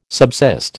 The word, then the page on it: subsist: American English